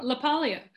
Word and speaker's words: LaPaglia: LaPaglia. – There it is. – There you go. There's the correct pronunciation.